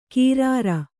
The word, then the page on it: ಕೀರಾರ: kīrāra